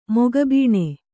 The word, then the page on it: ಮೊಗಬೀಣೆ: Kannada